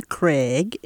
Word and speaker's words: Craig: My name is Gwen Craig, and if it's spelled later, it's G-W-E-N-N, Craig, C-R-A-I-G.